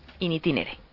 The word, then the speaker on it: in itinere: que luego me corregirá Fernando Vizcaíno. – Itinere. – Ay, qué difícil. Aquellos que se producen en los desplazamientos entre el hogar y los centros de trabajo. – ¿Te acuerdas que el año pasado ya tuvimos con esta palabra? – Es que... – Entonces me pillaron. – Dichosa palabreja. – Hoy te ha tocado a ti. – Hoy me reñirá. – Si te ha escuchado, desde luego. – In itinere. Lo digo bien.